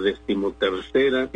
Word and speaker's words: decimotercera: Ahora bien, cuando estas dos palabras se unen, se hace una palabra compuesta, entonces se pegan las dos palabras y se tiene que pronunciar como la decimotercera entrada. Nótese, se pierde el acento de la palabra décima, el esdrújulo, y todo pasa a la segunda palabra tercera, incluido el género, es decir, es femenina la entrada, hay que decir decimotercera entrada.